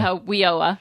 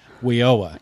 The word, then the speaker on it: WIOA: WIOA. – WIOA.